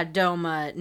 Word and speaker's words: Douma: Yeah, Douma near Douma.